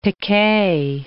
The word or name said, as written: piquet